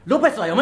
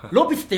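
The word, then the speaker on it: 呂比須: トゥーリオは 100 歩譲って分かるにしてもロペスは読めないだろう。風呂のロに比較の日に須田の須でロペスって、ロピスって読んじゃうだろう。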